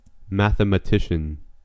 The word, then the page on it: mathematician: American English